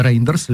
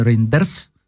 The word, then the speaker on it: Рейндерс: А комиссар ЕС по вопросам юстиции э, Дидье Рейн, э, Рейндерс, или Ен, э, э, Рейндерс, французским э, будет произноситься фамилия, сказал, что он открыт для обсуждения э, этого вопроса.